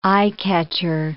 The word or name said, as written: eye-catcher